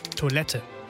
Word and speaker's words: Toilette: Im Französischen wird das E am Ende nicht ausgesprochen, im Deutschen hingegen schon. Toilette.